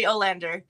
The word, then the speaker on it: Olander: Melody Olander.